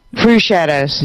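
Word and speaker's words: Crüxshadows: Crew Shadows or Crux Shadows. I never know how to pronounce it. – Crew Shadows. – Yeah. – Crew.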